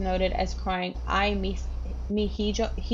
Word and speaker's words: hijo: Hijos. I haven't taken Spanish in a year. – It's just E. Don't pronounce the H, I think.